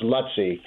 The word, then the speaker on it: Luizzi: It's actually pronounced Lutzi, but just Brian is Lutzi.